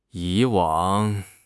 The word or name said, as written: 以往